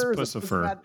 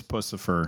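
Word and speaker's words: Puscifer: It's Pucifer. It's Pucifer. Okay. Think of Lucifer, but with a P.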